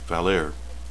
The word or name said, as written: valere